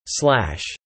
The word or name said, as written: slash